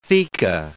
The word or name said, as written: theca